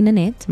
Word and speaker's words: Nanette: You can find me on my socials at Officially Nanette. My name is spelled N-A-N-E-T-T-E.